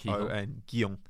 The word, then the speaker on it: Guion: I don't G-U-I-O-N. I'm going to go David Gijon. – G-U-I-O-N. – I like Gijon. Gijon. I'm going David Gijon now, apparently.